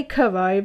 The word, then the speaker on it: Crrow: And I say Kuro because it's spelled CRROW 777. – So like the animal crow, but with more R's. – Yeah, yeah. – So like that. – Yeah, exactly like that.